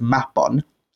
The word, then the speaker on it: Mabon: I know some people pronounce it. The word in Welsh would be pronounced Mabon.